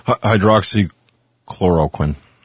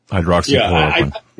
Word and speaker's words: Hydroxychloroquine: Hydroxychloroquine. Hydroxychloroquine.